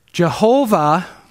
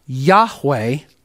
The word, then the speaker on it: Yahweh: Jehovah is a mispronunciation of the divine name, which should be something to the effect of Yahweh, because it is Yahweh with the vowel points for Adonai.